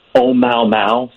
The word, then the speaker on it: Oumuamua: Yes, it is what is exciting about it, it's pronounced, Oh Mau Mau, sort of like that song, Day Bow Bow.